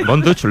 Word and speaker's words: Von Dutch: Así que, Von Dutch, ¿lo he pronunciado bien? – Sí, muy bien.